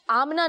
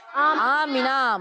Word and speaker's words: آمینہ: اس کا تلفظ آمنہ نہیں آمینہ ہے. کیا ہے؟ – آمینہ.